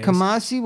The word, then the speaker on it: Kamasi: Kasami, it's like three days. Kamasi Washington, I never say his name right.